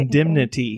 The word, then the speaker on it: indemnity: I mean, I just saw a pet sitter with a clause, an indemnity clause. Did I say that right? – Indemnity? – Yeah, right? Indemnity.